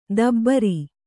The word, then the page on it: ದಬರಿ: dabari